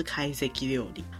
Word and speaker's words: 懐石料理: 或者是怀石料理，嗯、在日文里面，会席料理跟怀石料理发音都是一样，都是开 seki 料理。